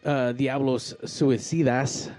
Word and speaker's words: Diablos Suicidas: And obviously today with Diablos Suicidas. – Ah, did I say that right? – There you go. You said it right.